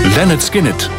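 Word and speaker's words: Lynyrd Skynyrd: Leonard Skinnerd, zo spreek je dat uit.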